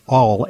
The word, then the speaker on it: Auel: I think it's pronounced Aul, A-U-E-L.